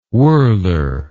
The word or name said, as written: wohler